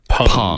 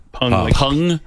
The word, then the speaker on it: Peng: And even though it is spelled P-E-N-G, the first lady's name is pronounced Pung. – Pung. – P-U-N-G.